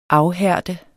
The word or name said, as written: afhærde